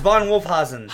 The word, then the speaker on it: Von Wolfhausen: I think there's a better way to pronounce it. And then I looked at it like, no, you nailed it. – Von Wolfhausen. – No, it's Von Wolfhausen.